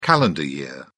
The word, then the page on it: calendar year: British English